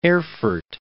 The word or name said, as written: Erfurt